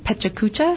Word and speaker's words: Pecha Kucha: There is a great event on Wednesday, November 14th called Pecha Kucha. – That's how you pronounce it. – Pecha Kucha roughly translates as chit-chat from Japanese.